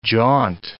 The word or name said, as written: jaunt